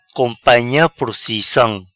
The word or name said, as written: Companha Procissam